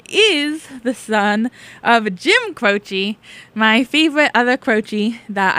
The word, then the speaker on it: Croce: Croce. Yeah, there's no other way to pronounce this pronunciation I've been sent. AJ Croce. Crouchy. No. Croce. Definitely Croce.